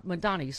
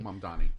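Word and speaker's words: Mamdani: There's got to be, like, progressive affordability candidates like Mayor Mondami. – Yeah. – Mondani, sorry. – Mondani.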